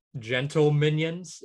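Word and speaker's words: Gentleminions: Gentle Minions, is that how you say it? – Oh yeah, yeah.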